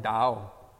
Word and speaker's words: Tao: And the union of yin and yang, husband and wife, you form Tao, which is pronounced or spelled T-A-O, reminding us of the cross. And it's interesting that Taoism, or the Tao, is represented as a D sound. E-A-O.